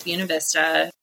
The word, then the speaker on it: Buena Vista: It is pronounced Buena Vista. Don't come at me.